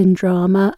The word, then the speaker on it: yndraamey: It's pronounced in drama and it's the Manx word for the theatre.